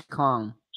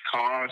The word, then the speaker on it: Kong: First name is Kong. – Kong, spell, can you spell it for me, Kong? – Kong, like King Kong, Donkey Kong, K-O-N-G. – King Kong, K-O-N-G.